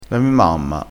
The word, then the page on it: mi: dialettale